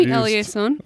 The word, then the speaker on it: Eliason: That's the initial pronunciation. That's the OG, like, way to say it. – See, that's what I'm saying. That's you can call me Eliason.